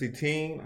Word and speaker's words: Etienne: I mean, you put in your third, fourth string running back, Travis Etienne, I think that's how you pronounce his name.